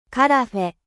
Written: carafe

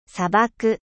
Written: desert

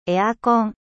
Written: climatiseur

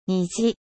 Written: 虹

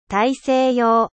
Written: ocean atlantique

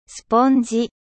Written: eponge

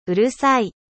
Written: うるさい

